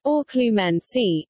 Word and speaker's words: Occlumency: Fact check. That is not the correct pronunciation, Lauren. These words from the wizarding world of Harry Potter are pronounced Occlumency and Legilimency.